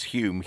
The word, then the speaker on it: Home: His name, by the way, Douglas Hume, Hume is spelt H-O-M-E, but in that wonderfully English way, it's not pronounced phonetically.